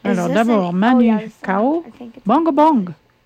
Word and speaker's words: Manu Chao: Eh ben, c'est Manu Chao. Ça se prononce comme ça? – Manu Chao. – Oh, oui. Manu.